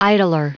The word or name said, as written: idler